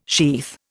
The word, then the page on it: sheath: Indian